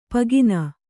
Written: ಪೂಗಣ್ಣ

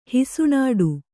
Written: ಹಿಸುಣಾಡು

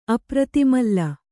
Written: ಅಪ್ರತಿಮಲ್ಲ

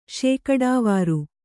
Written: ಶೇಕಡಾವಾರು

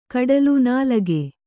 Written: ನಾಲಗೆ

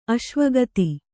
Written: ಅಶ್ವಗತಿ

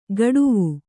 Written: ಕಡುವು

